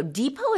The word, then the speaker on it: depot: Depot is spelled D-E-P-O-T. We don't pronounce the T at the end, so it's pronounced depot.